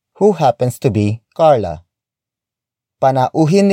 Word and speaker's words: panauhin: The next word is panauhin. Panauhin. Panauhin. Panauhin is spelled as P-A-N-A-U-H-I-N.